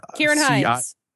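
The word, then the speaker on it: Ciarán Hinds: Kieran Hines.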